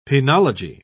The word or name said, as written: penology